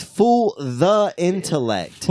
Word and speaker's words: Fool the Intellect: It is Fool the Intellect.